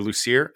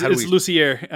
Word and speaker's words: Lussier: And this Friday on the Patreon, we are speaking to our pal Jermaine Lussier, or Lucier. I always get his name wrong. – It's Lussier. – Lussier. Yeah. – Yeah, he's corrected us multiple times, and I'm like you, still default in the Lucier.